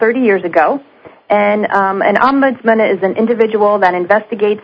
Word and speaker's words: Ombudsman: It's the Nursing Home Residents Bill of Rights and also the Long-Term Care Ombudsman Program, and I may not have pronounced that exactly correctly.